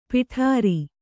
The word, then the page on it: ಪೌಡರು: pauḍaru